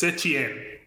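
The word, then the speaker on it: Setién: But basically that Setien basically told, by the way, is that how you say his name, Setien? – Our coach? – Yes. – It's Setien. – Setien, okay.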